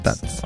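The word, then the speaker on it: Tad's: T-A-D apóstrofe S, Tats, Tats.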